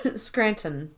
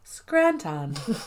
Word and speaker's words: Scranton: They nobody else pronounces the T. Scranton. – Yes, thank you. – But she was like, Scranton.